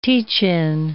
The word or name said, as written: teach-in